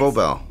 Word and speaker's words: Bobal: Is that how you say your last name? – It's Bobel. – Bobel. – Bobel. – Yeah.